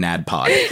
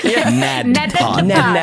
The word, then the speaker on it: NADPOD: Make sure you pronounce both of the D's in all three of the D's in NADPOD. NADPOD.